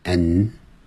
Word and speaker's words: and: 好，And, A-N-D, 这个 A-N-D 啊，标准的念法是 and, 可是你实际念的时候是念成 n, n, 它念得很弱，甚至有时候念成 n, n, n, 那个的 A-N-D 那个的 n 的，它的那个的都不念出来了。所以 John and I, and I 变成连音了，你看到没有？